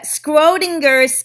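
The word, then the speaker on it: Schrödinger: So it's the Schrodinger's cat theory. Have you heard of that? It is spelled S-C-H-R-O-D-I-N-G-E-R. Schrodinger. S-C-H-R-O-D-I-N-G-E-R.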